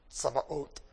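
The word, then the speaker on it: Sabaoth (צבאות): In English, we pronounce what they say, va, as ba. We put b's instead of v's. They're very close in many languages. And then the ot. So it's Adonai, the Lord, Sabaoth.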